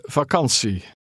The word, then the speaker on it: vakantie: Remember the letter combination T-I-E is pronounced C. – Ik heb 7 weken vakantie.